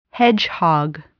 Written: hedgehog

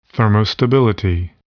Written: thermostability

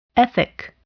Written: ethic

